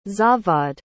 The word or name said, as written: zavad